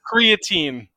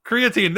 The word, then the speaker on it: creatine: Creatine. – Creatine, there we go.